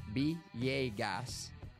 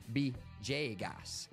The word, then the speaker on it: Villegas: In Spanish, Villegas is pronounced Villegas or Bjegas. The official language in Colombia is Spanish.